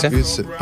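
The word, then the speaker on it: Ütz: It's a different project, the same artist behind it, but we called it Utz. It's spelled with the U, you know, the famous German, the U with the dots, you know, a T and a Z. – Utz. – Yeah, it's Utz, but it's, you know, it's just us on a different tip.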